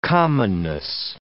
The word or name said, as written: commonness